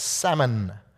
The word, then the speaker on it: Salmon: Now, we pronounce the fish as salmon, but I tend to think this gentleman's name was Salmon, S-A-L-M-O-N, just to be technical as a teacher there.